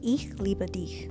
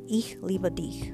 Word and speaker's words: Ich liebe dich: But if you want to say I love you, um, you would say Ich liebe dich, okay? That's spelled I C H space L I B E space D I C H. Ich liebe dich, okay?